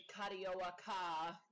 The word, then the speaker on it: Carioca: And when I went to like Google the pronunciation, because it's in Brazil, it's, you know, the Portuguese. That's how they pronounced it, Carioca.